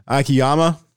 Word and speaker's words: Akiyama: He had zero home runs last year, and that's Shogo uh, Akiyama. Did I butcher his name? No, you did it.